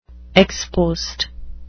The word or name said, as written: ex post